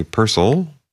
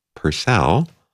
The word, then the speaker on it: Purcell: Right now, a suite of music by Henry Purcell, or if you wish, Purcell.